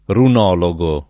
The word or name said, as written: runologo